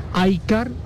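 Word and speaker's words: iCAUR: Aycar, ahora se escribe Aycaur, se pronuncia Aycar, presentó dos modelos que yo creo que van a ser la sensación.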